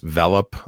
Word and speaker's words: Velop: Another kit that does well, that's also a mesh kit, by the way, is the Linksys Velop, I want to say.